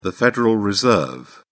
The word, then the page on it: federal reserve: British English